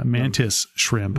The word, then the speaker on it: mantis shrimp: Not as badass as the mana shrimp. – Right? – Not as bad as... Did I say it right? – It's the mantis shrimp.